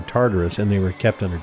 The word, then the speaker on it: Tartarus: You got the idea of Tartarus, which is the ship in Doctor Who. – Oh, no, that's the TARDIS. – Oh, never mind. – This has got an extra syllable.